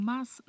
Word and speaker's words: mas: Esta frase, é muito importante prestar atenção que o mas não se pronuncia como no português, que falamos mais, é mas.